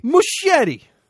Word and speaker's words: Muschietti: Muschietti. – Muschietti. No, but CH is a hard. – I'm going Muschietti here. – Muschietti. CH is a hard C. I mean, you know, assuming the Italian is correct.